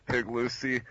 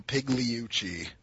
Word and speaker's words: Pigliucci: For anybody who's still confused as to how to pronounce Dr. Pigliucci's name, it is Dr. Massimo Pigliucci. I got that wrong? – The G is almost silent. So that's what I said.